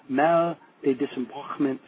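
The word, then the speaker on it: mal de débarquement: And the proper pronunciation is mal de disembarquement syndrome. So it's basically a French term for getting sick when leaving the ship.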